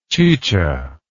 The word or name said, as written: chicha